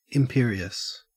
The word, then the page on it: imperious: Australian English